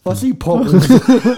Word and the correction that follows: Osteoporose: Wie, wie, heißt das richtig? Ossi-Prognose, nicht? – Osteoporose? Ja, richtig.